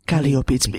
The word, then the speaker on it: Kaliopi: Kaliopi. Kaliopi. It's really, I pronounce it Greek way.